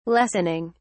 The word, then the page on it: lessening: British English